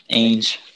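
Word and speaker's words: Ainge: I know it sounds a little strange, but I think him and Danny, I think his last name is pronounced Ange or Ang. – Ange.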